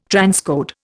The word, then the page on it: transcode: Indian